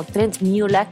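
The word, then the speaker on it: Trent Møller: porque se trata de Trent Moller, o mejor pronunciado como Trent Mueller, que lleva muchos años en la escena musical.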